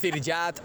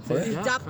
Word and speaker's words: Zirjap: Son dos sílabas, coño, Zir y Jap. – Zirjap, tampoco es tan difícil.